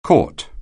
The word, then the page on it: court: anglais